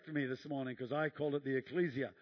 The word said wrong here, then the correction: ecclesia: and he says it's the ecclesia, and I said, oh, that's good, and right, you just got to get the emphasis on the right syllable, obviously.